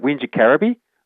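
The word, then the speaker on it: Wingecarribee: It's a big word. I pronounce it Wingacarabee.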